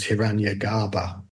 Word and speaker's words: Hiranyagarbha: Hiranyagarbha. I might not get the pronunciation. – No, that's right.